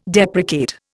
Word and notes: deprecate: Indian

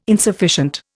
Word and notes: insufficient: Indian

